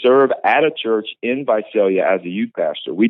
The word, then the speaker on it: Visalia: Visalia, yeah.